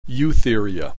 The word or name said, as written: Eutheria